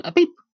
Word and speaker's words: Apep: Not like the peep, but, you know, Epep. During Ra's nightly voyage. Or A-P-E-P.